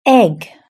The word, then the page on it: egg: British English